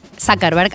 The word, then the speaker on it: Zuckerberg: Con Mark Zuckerberg,